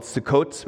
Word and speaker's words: Sukkot: And they go out, back to their homes in Goshen, and then they go out through what's called Sukkot, which is like spelt Succoth, if you're just trying to figure out the pronunciation there, where they bypass Pitom, so they kind of just, that's why it's not in the itinerary.